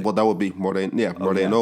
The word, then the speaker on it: morena: Now, I think in Espanol it is pronounced Morena.